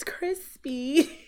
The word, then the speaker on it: Chrispy: And my name is Crispy. And that's spelled C-H-R-I-S-P-Y.